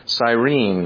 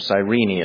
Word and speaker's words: Cyrene: As verse 21 of this chapter tells us, he was from Cyrene, or Cyrene as it's sometimes pronounced, located in modern-day Libya in North Africa.